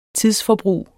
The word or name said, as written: tidsforbrug